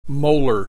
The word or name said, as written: molar